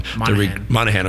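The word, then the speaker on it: Moynahan: Bridget Moynihan, is that how you say her name? The girl that's on Monahan. – Okay, yeah.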